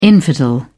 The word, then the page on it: infidel: British English